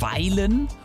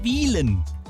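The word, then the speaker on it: Wyhlen: Ortsfremde sagen oft Weilen oder sogar Wühlen. Es heißt aber Wielen, auch wenn es mit Y geschrieben wird.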